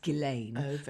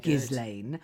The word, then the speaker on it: Ghislaine: It's incredibly sad in the end, and of course the Maxwell notoriety continues with Ghislaine Maxwell, and that is how you pronounce it. It's Ghislaine, not Ghislaine.